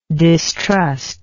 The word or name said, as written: distruster